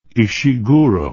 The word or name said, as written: Ishiguro